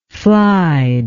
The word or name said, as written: flied